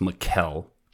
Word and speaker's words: Mekelle: I think it's pronounced Mekelle, but yeah, feel free to correct me if I'm wrong.